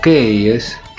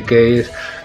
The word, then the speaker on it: Keyes: Así que Bloom, como es vendedor de, avisos publicitarios, tiene que cerrar trato con un cliente que se llama Alejandro Keyes, que se escribe K-E-Y-E-S, casi como si fuera keys en inglés, que quiere decir llaves.